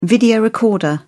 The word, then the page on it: video recorder: British English